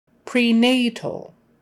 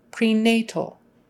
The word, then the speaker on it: prenatal: Prenatal, emphasis on the nay. Prenatal, prenatal. And the AL is kind of all together, just move your tongue up for the L.